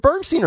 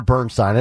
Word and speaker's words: Bernstein: Carl Bernstein, who is it Bernstein or Bernstein? I never know how to pronounce that. Steen. Okay.